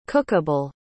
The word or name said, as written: cookable